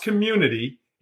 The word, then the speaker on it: CXMMUNITY: The reason why it's spelled CXMMUNITY is that the X is the only character that is represented on your controller as well as your keyboard. – Oh. – So Community, it's pronounced Community.